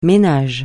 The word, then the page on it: ménage: francais